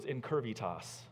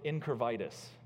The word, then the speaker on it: incurvitas: But one time in his teaching, he was talking about a certain Latin word, and that Latin word was incurvitas. And he pronounced it differently in order to help take his point home. And he pronounced it incurvitis. And he says it sounds like an awful disease.